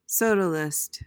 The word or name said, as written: sodalist